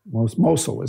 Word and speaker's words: Mosul: And there's this one place, a little town at Mosul, Mosul is how they say it.